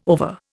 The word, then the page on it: over: Indian